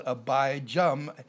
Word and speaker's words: Abijah: A-B-I-J-A-H. Some places it's called Abijam, A-B-I-J-A-M. The more correct pronunciation and spelling would be Abijah.